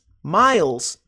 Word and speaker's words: miles: Miles. Preste atenção na pronúncia porque é muito diferente da escrita. Não é miles, é miles.